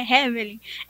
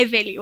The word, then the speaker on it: Hevelly: É por causa do H, ele se escreve H-E-V-E-L-L-Y, aí muitas pessoas pensam que é Evelyn, mas não é Evelyn, é Evelyn, o H é mudo.